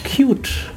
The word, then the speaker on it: Qt: Ich glaube Qt.